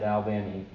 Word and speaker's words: Albany: So when we get here, I made sure I said Albany.